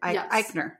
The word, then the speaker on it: Eichner: Directed by Nicholas Stoller, based on a screenplay he co-wrote with Billy Eichner. – Oh, cool. – Is that how we figured out? Eichner.